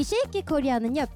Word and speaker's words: 쉐이키 코리아: 여러분 발음 실수하시면 안 돼요. 쉐이키 코리아입니다. 네, 이 쉐이키 코리아는요 퓨전 국악 밴드 두 팀이 각자 연주를 하고 같이 합동 무대까지 여러분들께 들려드리는 네 방송입니다.